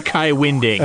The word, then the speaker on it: Winding: Jazz people were saying, I met Kai Winding, he pronounced it Kai Winding.